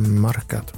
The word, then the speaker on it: Marca: Är det så man uttalar? – Marca. – Marca? – Jag vet. – Nej. Marca.